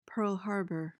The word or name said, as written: pearl harbor